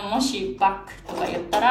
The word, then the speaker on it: バッハ: 作曲家のバッハって英語ではバックって発音するんですね